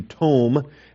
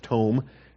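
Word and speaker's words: Tome (תֹּם): It's not Tom, it's Tome, okay, with a long O there, the cholam vowel.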